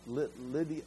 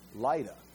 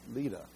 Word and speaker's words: Lydda: How do you say this? Lydia. I want to say Lydia, but that's not right. Lydda. Lydda. Why do I not know how to say that? It's Old Testament Lod, L-O-D.